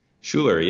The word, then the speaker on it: Schuller: Have I pronounced that right, Jason? – Schuller, yes. Schuller. You got it pretty much on the nose.